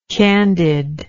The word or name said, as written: candidness